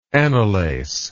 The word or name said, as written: anelace